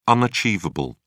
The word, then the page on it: unachievable: British English